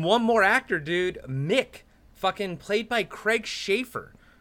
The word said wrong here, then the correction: Sheffer: from Sheffer. – Okay, is that how you pronounce it?